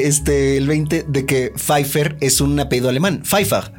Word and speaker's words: Pfeiffer: O sea que se dice Pfeiffer, no Pfeiffer, porque la pronunciación alemana es Pfeiffer.